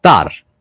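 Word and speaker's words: Tár: Poi Tar, con l'accento sulla A.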